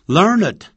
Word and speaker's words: learned: Learned 当形容词用，一定要念成 learned，变成两个音节。如果不用这个字，还有另外一个字，博学多闻的，也可以用 He is well read，他书读得很多。